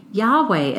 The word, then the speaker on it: Yahweh: Yahweh, as we say in English. Many Christians pronounce it Jehovah, and it is often translated into our English Bibles as Lord.